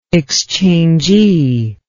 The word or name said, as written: exchangee